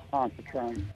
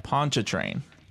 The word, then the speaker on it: Pontchartrain: I'm on the north shore of Lake Pontchartrain. – Pontchartrain.